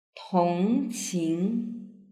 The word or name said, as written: tongqing